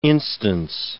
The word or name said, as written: instance